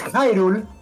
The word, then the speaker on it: Hyrule: Que me gusta porque lo pronunció muy mal. Es Hyrule Warriors, que es el juego muy como Dynasty Warriors basado en el mundo de Zelda.